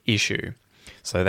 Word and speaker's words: issues: That is an issue. So they've been issued, right? They've been given out. They've been, well, they're being sold. So shoes is the long oo vowel sound. Shoes, shoes, and issues is exactly the same word, but with the vowel sound I in front of it, and that's where the emphasis is.